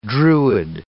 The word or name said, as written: druid